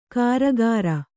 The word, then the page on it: ಕಾರಗಾರ: Kannada